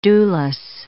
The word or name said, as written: dewless